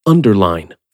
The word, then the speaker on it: underline: Please don't kill me, but in some three-syllable words, this I-N-E suffix is indeed pronounced like mine, dine, and fine. Take the word underline, for example, which translates the French souligner. If underline keeps the ein pronunciation of the I-N-E suffix, it's because the first syllable of the word is stressed. Underline.